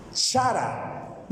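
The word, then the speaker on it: Chara (χαρά): O mejor dicho, ¿cómo se escribe gozo en, el griego? Chara. Diga conmigo, chara. – Ahí está, chara. ¿Cómo es? Chara.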